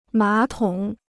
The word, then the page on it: 马桶: mǎ tǒng